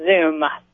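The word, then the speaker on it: Xoom: It is the Zoom, as in Zoom, Zoom, Zoom.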